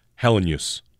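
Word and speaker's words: Helenius: And I'm pretty sure it is Hellenius. – Hellenius. – That's it. Three syllables, Hellenius.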